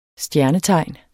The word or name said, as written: STJERNETEGN